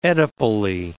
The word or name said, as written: oedipally